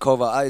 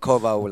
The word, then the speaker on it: Koval: I know you were born in Sweden, so do you go Koval or Koal? – I pronounce it Koval. It's been butchered so many times, so, always. But Koval like a V.